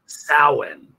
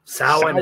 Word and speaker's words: Samhain: Samhain is how it's pronounced. The way most people would pronounce it is Samhain. – That's what it looks like. – Probably yeah. Because of Glenn Danzig's band from back in the 80s. But no, it's pronounced Samhain. It's like ancient Gaelic, that kind of thing.